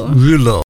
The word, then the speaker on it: Reuleaux: They are, now I'm going to guess at this pronunciation, a Rulo triangle. – It's which one? – R-E-U-L-E-A-U-X. – Rulo. – Rulo. Rulo.